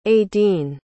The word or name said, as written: adene